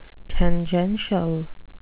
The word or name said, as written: tangential